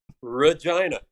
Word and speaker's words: Regina: It rhymes with vagina. – Yeah. Regina.